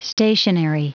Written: stationery

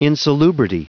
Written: insalubrity

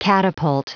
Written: catapult

